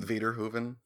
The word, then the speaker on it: Verhoeven: Verhoeven. – Yeah, that's how you say it.